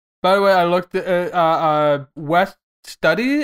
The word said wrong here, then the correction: Studi: Is that how you pronounce his name? – Studi. – West Studi.